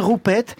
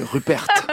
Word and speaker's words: Rupert: Petite parenthèse, c'est pas Roupette, hein, c'est Rupert. – Ah, on prononce comme ça. – Oui, oui, Roupette, c'est autre chose.